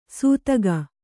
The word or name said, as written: ಸೂತಗ